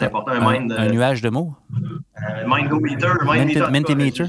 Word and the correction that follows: Mentimeter: Je, je, prononce peut-être pas bien, mais Mentimeter, c'est ça, Mentimeter, c'est ça.